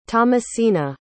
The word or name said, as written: tomasina